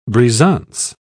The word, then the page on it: brisance: British English